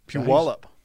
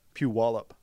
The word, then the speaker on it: Puyallup: Puyallup. See, I had a teammate from there. – I knew you were going to tell me the right way to say that. Say that again. – Puyallup. – Puyallup.